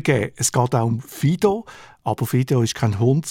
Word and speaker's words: FIDO: Auf Englisch wird es FIDO ausgesprochen.